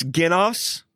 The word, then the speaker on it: genos: It's genos. Uh-huh. So it's spelled G-E-N-O-S. So this is like the root word where we would get genes or genetics or generation.